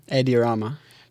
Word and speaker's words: Edi Rama: Edi Rama.